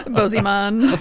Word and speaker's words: Bozeman: I was like, is either Bozeman or Bosman? Could be Bozeman. – Bozeman.